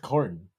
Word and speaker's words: Corden: Why did you say it like that? – Corden. Is that not how you say his name? – No, I think it's just Corden.